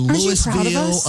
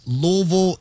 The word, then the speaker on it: Louisville: Y'all aren't saying Louisville or Louisville. It's Louisville, and I'm so happy and so thankful that y'all are saying it correctly. Louisville.